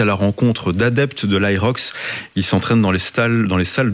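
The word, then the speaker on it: HYROX: Ça s'écrit H-Y-R-O-X. C'est l'aérox.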